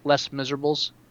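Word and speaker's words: Les Misérables: Just for the record, it's pronounced Les Miserables.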